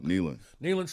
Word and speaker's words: Neyland: Neyland? I always say it wrong. – Neyland Stadium.